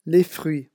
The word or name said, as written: les fruits